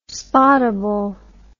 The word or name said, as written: spottable